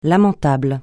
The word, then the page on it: lamentable: francais